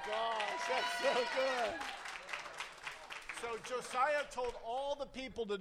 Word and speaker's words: Josiah: Josiah.